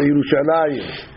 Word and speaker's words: ירושלים: Normally it's spelled Yerushalem, but we add a yud to it. We say Yerushalayim. Yerushalayim makes it plural.